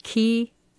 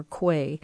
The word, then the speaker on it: quay: I've been pronouncing it K, but it's Q-U-A-Y. It's an interesting word. It can be pronounced key, K, or quay.